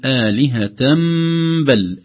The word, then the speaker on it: آلهة بل: لكن هنا نطقها سيصبح حرف ميم، فنقول آلهة بل آلهة بل، طبعا عند الوصل فقط.